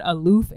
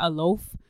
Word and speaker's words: aloof: But in the process, you also want your child to be, I was pronouncing it wrong this whole time. It's what? – Called aloof and not aloaf.